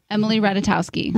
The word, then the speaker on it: Ratajkowski: Emily Ratajkowski. – Ratajkowski. – Ratajkowski.